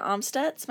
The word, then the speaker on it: Amstutz: You can find me on all social media platforms at Sandra Amstutz.